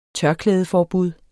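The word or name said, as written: TØRKLÆDEFORBUD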